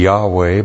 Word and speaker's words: Yahweh: The tetragrammaton YHWH, this is the holiest name of God in the Old Testament scripture, so holy that the Jews never would pronounce it, if you pronounce it, it would be Yahweh, but they never pronounced that.